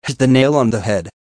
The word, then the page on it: hit the nail on the head: American English